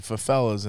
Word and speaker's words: favelas: But the favelas and did I say that right? – Favelas, yes.